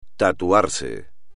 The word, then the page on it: tatuarse: espagnol